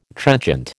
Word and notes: trenchant: American English

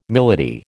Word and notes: milady: American English

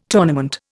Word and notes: tournament: Indian

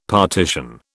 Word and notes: partition: British English

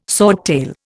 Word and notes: swordtail: Indian

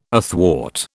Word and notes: athwart: British English

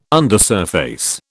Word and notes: undersurface: British English

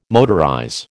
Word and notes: motorize: American English

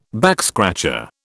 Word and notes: backscratcher: British English

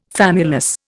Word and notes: famulus: Indian

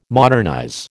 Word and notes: modernize: American English

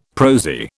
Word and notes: prosy: British English

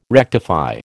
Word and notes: rectify: American English

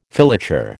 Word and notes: filature: American English

